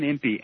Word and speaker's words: Impe: Is it pronounced Impe? – I believe it's Jack Van Impe.